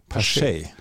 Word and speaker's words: Pache: Er heißt übrigens Christian Pache, habe ich eben noch gelernt. – Pache. Ja gut, ich meine, wie lange machen wir das jetzt? Wir sind in der 12. Saison und ich meine Namensaussprache. Da kann man keine Entwicklung feststellen. – Ach ja. Aber es gibt bei Baseball Reference gibt es eine Seite, wo du Aussprachen hast. Und da wurde mir gesagt, Christian Pache heißt er.